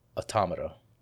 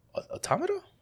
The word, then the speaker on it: Automata: I played Nier Automata, and that game, let me tell you. – Is it Automata or Automatica? – Automata. – Okay. – Yeah. So, Automata? Automata. Yeah.